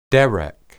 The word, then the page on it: derek: hebrew